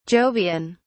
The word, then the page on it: jovian: British English